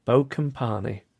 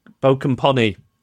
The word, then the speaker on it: Bokompani: Sincerely, Pete Bokompani, which he spells it out phonetically for me at the end. Bokompani. Bokompani. Bokompani. – Bokompani.